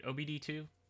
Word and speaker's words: OBD2: OBD2? – Yeah.